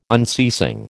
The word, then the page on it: unceasing: American English